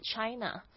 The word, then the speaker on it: China: 所以要特别注意的是，这个 CH 呢，其实它大部分的时候是发这个 CH 的声音，像我们的 China 不是吃哪哦，是 China，所以要特别注意一下这个 CH 的发音，有点像是说去哪里的那个 CH， OK，就是但是不要发出吃的那个声音 China Chicago，或者是 CH 有时候会发 sh 的声音， sh 就是 sh 也就是无声的，不会碰，就是你在摸这个声带的时候呢，不会感觉到有震动。